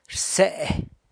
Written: rse

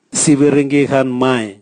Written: siviringi gan mai

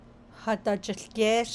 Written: hadajiłgéésh

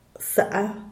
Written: saa

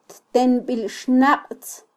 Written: ttenbilxnaqtz

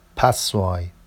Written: passoɑj

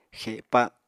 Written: xepa

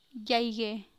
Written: Quialana

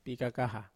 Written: pikakaha

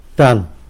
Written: Tan

